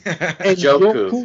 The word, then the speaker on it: Njoku: Njoku.